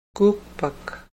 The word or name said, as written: kuukpak